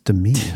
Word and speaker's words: Demi: And so, as you know, famously, he was married to Demi Moore for several years. – It's pronounced Demi. – I'm sorry, Demi Moore.